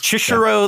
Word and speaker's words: Chishiro: It's Chishiro? – Yes. – Chishiro, yeah.